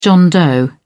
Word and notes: john doe: British English

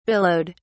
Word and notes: billowed: British English

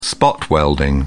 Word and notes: spot welding: British English

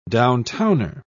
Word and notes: downtowner: British English

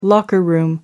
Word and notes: locker room: American English

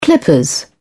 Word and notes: clippers: British English

